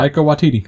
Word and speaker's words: Taika Waititi: Never know how to say his name. – I just told you. Yeah. Taika Waititi.